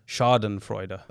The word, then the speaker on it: Schadenfreude: firstly, I'm glad I actually looked up how to pronounce it, because I was going to say Schadenfreude, but it's... – And what is it then? – Schadenfreude. – Okay. – Yeah. It's actually pronounced that way.